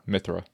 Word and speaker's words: Mithra: Pyra or Mithra? Is it Mithra or Mithra? – Mithra. – Mithra. – Like myth. Mithra.